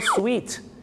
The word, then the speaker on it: suite: นหน้างงเลยครับ the suit suits you ก็แปลว่าสูทตัวนี้เข้ากับคุณมากครับแต่ว่าพอคำว่าสูตร SUIT เพิ่มตัว E เข้าไปด้วยเนี่ยนะครับเหมือนกับอย่างเช่นที่ห้องโรงแรมที่เป็นห้องชุดมีทั้งห้องรับแขกด้วยเนี่ยชาวไทยไปบอกว่า I want the suit room I want the suit room มันไม่ใช่ห้องเก็บสูตรนะครับคุณผู้ชมห้องสูตรนะครับผมที่จริงแล้วต้องเรียกว่า suite SUITE ครับส่วนพี่น้องชาวไทยออกเสียงว่าห้องสูตรแต่ฝรั่งจะเรียกว่า suite